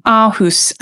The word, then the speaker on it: Aarhus: And you reminded me when you were talking of, I've just come back from Denmark and I was in the art museum in Aarhus, it's pronounced in Denmark, I was corrected,